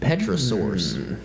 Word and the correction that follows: Pterosaurs: Pterosaurs, if you're not supposed to pronounce the P there.